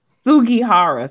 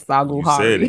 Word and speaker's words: Sugihara: Sugihara. See, I was close. I said Sagihara.